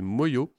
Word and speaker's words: Manglo: Là aussi une belle découverte avec ce groupe, on prononce, enfin ça s'écrit M-A-N-G-L-O sur l'album et c'est Moyo, les chanteurs de Saint-Vincent-sur-Oust et ils chantent les écoliers.